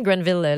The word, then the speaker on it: Granville: En 1883, il y a Joseph Mortimer Granville, m'a dit Granville parce que c'est même ça s'écrit, mais il s'appelle vraiment Granville, l'inventeur du vibromasseur électromécanique,